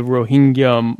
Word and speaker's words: Rohingya: So you will see someone talk about an ethnic genocide that's happening to the Rohingya Muslims in Myanmar right now.